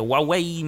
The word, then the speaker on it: Huawei: Skoro Huawei wymawia się Huawei, no to może i tu jakoś będzie dokładnie.